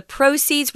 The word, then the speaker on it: proceeds: So, proceed or proceed is the verb, but proceed is a noun, and we do pronounce it differently. The accent comes on the first syllable proceeds.